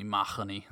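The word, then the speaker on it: Mahony: But we were originally the O'Mahonys, which I was told from a librarian in Madison, Wisconsin, that I'm saying my name wrong. It's supposed to be Mahoney.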